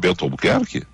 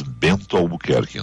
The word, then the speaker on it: Bento Albuquerque: Por sinal, tem que cuidar cada vez que diz Bento Albuquerque. Né? Tem que dizer bem pronunciado isso, porque eu já ouvi alguns colegas nossos da imprensa pegar e dizer Bento Albuquerque, e aí vai num Beto Albuquerque da ah, vida.